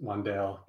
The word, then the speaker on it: Wandale: So it's just Wandale. It's not as cool as I tried to make it out to be. Maybe I thought it had like an accent on the E.